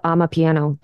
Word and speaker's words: Amapiano: But as you get into like Central Africa, the Afrobeats and the, you have to tell me if I'm saying this right, Amapiano. – Very close. Amapiano. Yeah, you got it.